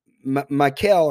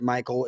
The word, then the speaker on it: Michal: And now, Michal, or how they sometimes pronounce it, Michal, it's M-I-C-H-A-L.